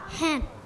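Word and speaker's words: hat: ไหมคะกลุ่มที่ 3 ใช่ไหมคะเอาเลยค่ะ Head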